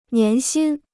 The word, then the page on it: 年薪: nián xīn